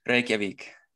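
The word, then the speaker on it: Reykjavík: Reykjavik. – Oh, you've even got the accent and everything. – You have to roll your R's and yeah, you have to have it there.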